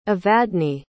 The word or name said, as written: evadne